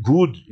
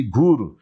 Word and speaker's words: Goor: They pronounce it good. It's a mistake. You see, Gur. G-O-O-R.